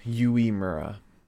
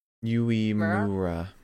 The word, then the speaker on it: Uemura: Also, Marion says it's pronounced Uemura. Uemura. Uemura.